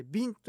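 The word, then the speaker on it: 保栄茂: マッチョビンドッサイ。それでは次のコーナーです。沖縄のなんだ、沖縄の地名読みにくいって言いますよね。保険の方に栄える、そして草木が茂るの茂ると書いて、保つ栄える茂ると書いてビンと読みます。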